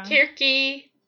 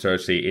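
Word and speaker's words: Circe: I mean, I have no doubt that it is pronounced Cersei in English, but that's not how it's pronounced originally.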